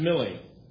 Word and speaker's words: Milley: Is his name pronounced Miley or Millie? – Millie Vanilli.